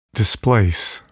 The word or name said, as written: displace